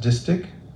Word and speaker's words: distich: It's pronounced distich.